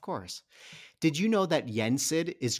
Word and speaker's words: Yensid: Yensid.